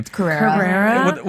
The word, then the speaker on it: Carrera: Carrera.